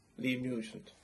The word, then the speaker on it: Liam Nugent: Liam Nugent.